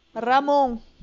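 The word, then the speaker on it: jamón: Carrom é, presunto é Ramon. Ramon. E se escreve Jamon.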